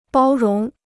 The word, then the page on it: 包容: bāo róng